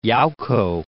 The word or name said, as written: Yauco